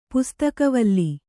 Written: ಪುಸ್ತಕವಲ್ಲಿ

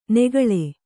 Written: ನೆಗಳೆ

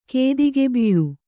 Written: ಕೇದಿಗೆಬೀಳು

